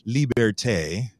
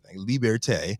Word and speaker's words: Liberté: This spring, they'll be launching their new luxury brand, Liberté. That's how it's pronounced. That's what he said. Liberté, to address what they say is a gap in the market and that's begging to be filled.